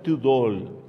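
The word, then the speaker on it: Thödol: El bardo Tudol, se pronuncia Tudol, porque tiene diéresis en la primera O.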